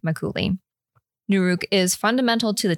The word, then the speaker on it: Makgeolli: So I found a like Korean, like, let's learn how to say it on YouTube. – Okay. – And it sounded like Makgeolli.